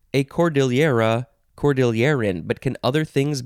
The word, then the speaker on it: cordillera: Cordillera or cordillera or cordillera. Cordillera. I think the best pronunciation is probably cordillera. It is a noun from 1704.